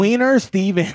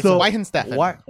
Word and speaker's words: Weihenstephaner: Wiener Steven. – No, it's Weihenstephaner.